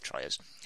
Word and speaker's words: Troyes: Trois? It's a French name. It's spelled Troyes, so we call it Troyes.